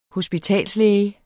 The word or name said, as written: hospitalslæge